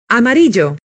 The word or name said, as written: amarillo